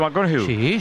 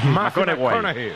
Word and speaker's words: McConaughey: ¿Matthew McConaughey? – Sí. – Que hay que ir corrigiendo la pronunciación. – McConaughey.